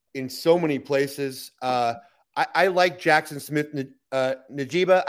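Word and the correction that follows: Njigba: I'd like to know how to pronounce his name, actually. – In Jigba. – Okay, I got pretty close.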